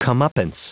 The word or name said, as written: comeuppance